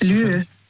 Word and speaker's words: Lieu: Lieu. Lieu. – Lieu? – Also Lieu, das schreibt man, also L-I-E und dann U.